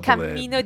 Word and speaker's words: cammino: For example, il cammino di Santiago with a double M. – Let's hear those two words together, please. – Camino, cammino. – So I hope you can hear the difference there in pronunciation. Camino, one M, cammino. With two M's and a little bounce in the middle.